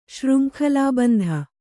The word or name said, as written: ಶೃಂಖಲಾಬಂಧ